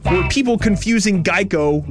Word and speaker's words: Geico: Geico Gecko. – That's correct, Brooke. Hey. The original idea was that a gecko could help people remember the name Geico, which often got pronounced like gecko.